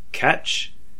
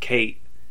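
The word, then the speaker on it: Cait Sith: Ketch, or as a lot of people pronounced it, Kate, is spelled C-A-I-T. Mm-hmm. And she, which a lot of players pronounce as Sith, because it's spelled that way, like the evil Star Wars characters, S-I-T-H. – Yep. – So it looks like it's Kate Sith. – Yeah, but it's Gaelic, so it's, you know, it's pronounced differently.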